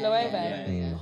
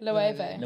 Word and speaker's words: Loewe: The one designer I like really recently is Lawi. Lawi? – Yeah. Loewe. – Oh, man. How you say it? – Loewe.